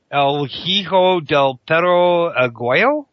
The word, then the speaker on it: El Hijo del Perro Aguayo: El Hijo del Perro Aguayo? – Close enough.